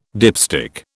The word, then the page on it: dipstick: British English